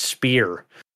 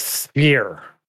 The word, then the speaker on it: sphere: I just recently learned that it's not pronounced spear. That H is like sphere. – The PH sounds like an F.